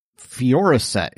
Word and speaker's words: Fioricet: The search of the items brought into the hotel room by Salt and Ramos revealed lidocaine, antibiotics, prescription antibiotics, Xanax, and Foresight? – Fioricet is Fioricet? How I pronounce it.